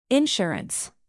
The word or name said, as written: insurance